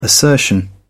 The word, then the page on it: assertion: British English